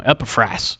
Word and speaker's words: Epaphras: But he sent a man there, a man named Epaphras. I almost said his name wrong. Epaphras.